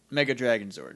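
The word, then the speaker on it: Mega Dragonzord: they should have gotten Mega Dragon Zord. Am I saying that right? – Yeah.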